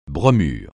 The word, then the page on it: bromure: francais